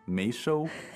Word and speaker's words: 没收: Right, it's a character with multiple readings. – 对。 – So in this case, it's 没。 – 哎。 – 没收 means to confiscate.